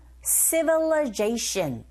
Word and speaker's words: civilization: 그리고 civilize가 아니라 이때 발음은 강세 앞부분에 이 부분에 있어요. 뒤에 나온 I 부분에 강세가 없기 때문에 이 소리 자체가 약화되어져서 어처럼 발음이 들립니다. 그래서 civilization 이렇게 발음하셔야 돼요.